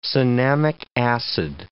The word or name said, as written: cinnamic acid